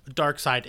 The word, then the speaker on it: Darkseid: Darkseid is.